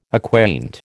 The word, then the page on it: acquaint: American English